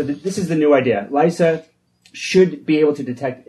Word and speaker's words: LISA: But in 10 years, we're going to have LISA, how are they pronouncing it? – LISA.